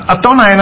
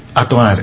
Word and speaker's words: Adonai: 那个四个大写的就是 Jehovah，其实 Jehovah 这个字啊不是这样念，应该是 Yahweh，那这个字本来不能念，后来就是把另外一个希伯来语叫做 Adonai， Adonai 是主的意思，用这个来取代。那因为希伯来语有 22 个字母，只有子音没有母音，有子音没母音就是不能念，不能念。所以耶和华这个字字母是四个 YHWH，你念念看 YHWH 怎么念啊，没母音啊，希伯来文是没母音的。那 Adonai 呢，哎 Adonai A Adonai 是 A 多一个 O Nai N-A-I，所以 A-O-I 把它三个母音摆进 Y-H-W-H 就是 Y-A Yah H 后面加个 O Yah Ho，然后最后 W-H 加个 A-I Wah Ho Wah，就这样跑出来的了。